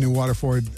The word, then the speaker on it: New Waterford: I think people from New Waterford definitely pronounce the Ford rather than New Waterford.